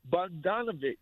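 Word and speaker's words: Bogdanović: But listen, Bogdanovich, right? That's how you pronounce his name? – Mm-hmm.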